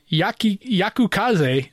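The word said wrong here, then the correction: Yukikaze: I can't say Yuki Kaze. – Yuki Kaze. It's four syllables. Yuki Kaze.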